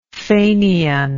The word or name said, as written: faineancy